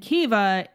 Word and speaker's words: Caoimhe: And Kiva is spelled C-A-O-I-M-H-E, as you can see, an English reader in the Western world, that might be a little difficult to pronounce because it's clearly Irish.